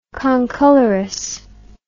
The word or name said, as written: concolorous